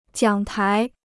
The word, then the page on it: 奖牌: jiǎng pái